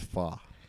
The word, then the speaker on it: pho: And they both said that it's pho. – Everybody said it is pho. It's pronounced pho.